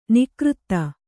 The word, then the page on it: ನಿಕೃತ: nikřta